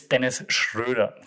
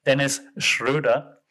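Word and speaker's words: Schröder: And Dennis Schroeder, by the way, it's Dennis Schroeder. Just saying, so he's a German, so that's why I can pronounce his name. Dennis Schroeder.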